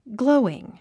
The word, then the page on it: glowing: ɡlóuiŋ; グロォイン